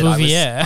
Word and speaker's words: Bouvier: Bouvier.